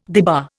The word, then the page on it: debar: Indian